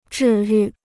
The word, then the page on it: 治愈: zhì yù